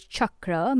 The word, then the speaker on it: chakra: The actual correct pronunciation of the word is chakra, meaning wheel.